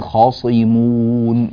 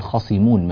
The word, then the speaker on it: خاصمون: كذلك ننبه إلى قوله تعالى خاصمون في الآية نفسها: بل هم قوم خاصمون. نفخم الخاء ونفخم الصاد ولا نضعف تفخيم الصاد، لا نقول خاصمون مثلا فتكون الصاد قريبة من السين، لا، هذا غير صحيح.